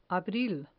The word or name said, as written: abril